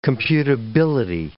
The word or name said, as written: computability